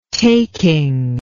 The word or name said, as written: taking